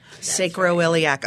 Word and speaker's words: sacroiliac: Find out more about the sacroiliac joint. Did I say that right? Sacrum. Sacroiliac. Right. Okay, I'll remember that now.